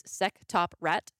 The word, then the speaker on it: SectopRAT: Bing ad for NordVPN leads to, I prefer to pronounce this as Sectoprat, but it is Sectoprat.